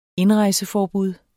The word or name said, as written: indrejseforbud